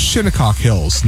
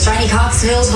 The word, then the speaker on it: Shinnecock Hills: And yes, it is pronounced Shinnecock Hills, not the way Yasmin Rodriguez on Channel 4 pronounced it.